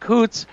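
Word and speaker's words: Coutts: And there was a video that came out of, I think it's pronounced Coots, where the Canadian truckers and the police are actually embracing each other. And they're saying, we may be leaving Coots, but we're not leaving our fight.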